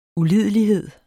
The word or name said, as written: ulidelighed